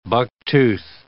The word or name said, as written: bucktooth